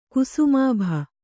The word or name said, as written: ಕುಸುಮಾಭ